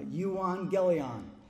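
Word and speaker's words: euangelion (εὐαγγέλιον): It is euangelion.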